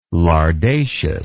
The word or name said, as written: lardaceous